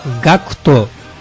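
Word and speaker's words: GACKT: Bueno, en Japón pronunciamos GACTO en vez de decir GACT, ¿vale? GACTO es auténtico pronunciación. – Eso, para que lo sepáis pronunciar bien, es GACTO.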